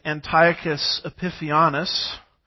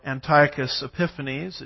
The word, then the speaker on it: Antiochus Epiphanes: A man named Antiochus Epiphanes, or Antiochus Epiphanes, it just depends on which syllable gets the emphasis, is one of my professors used to say.